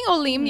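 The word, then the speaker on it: Olim (עולים): And it's just like, I don't know, we're getting into another topic of like discrimination, but I just feel like being Olim or Olo, did I say that right? Being Olim, mm-hmm. Plural collective. – Yeah, plural Olim.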